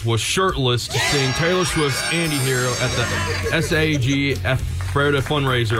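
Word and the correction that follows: SAG-AFTRA: I can't pronounce that. – AFTRA. SAG. Just say SAG AFTRA. – SAG AFTRA.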